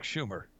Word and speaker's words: Schumer: That's Chuck Schumer. Rhymes with humor.